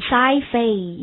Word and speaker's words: sci-fi: 那么这个地方直接把它读成 sci-fi, 一般是读成 sci-fi movie, 对不对？